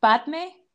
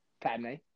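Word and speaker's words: Padmé: Okay, it's really similar. It's Padme. – Padme. – Amidala.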